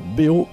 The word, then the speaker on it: bev: Et on va écouter BO, BO, vivant, vivant. Ça s'écrit attention, BEV, et non pas BEO. Le V se prononce O.